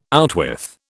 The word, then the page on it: outwith: British English